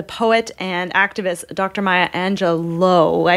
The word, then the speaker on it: Angelou: And we were told that the correct pronunciation is Angelou.